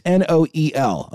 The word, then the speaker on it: Noel: It's NOEL, okay? It's like Noel, but pronounced Noel.